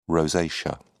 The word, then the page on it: rosacea: British English